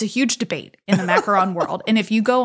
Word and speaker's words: macaron: But yes, technically it is macaron. It is spelled with one O, M-A-C-A-R-O-N. And then you have the macaroon, which is technically the meringue and coconut confection that's usually like dipped in chocolate. That has two O's.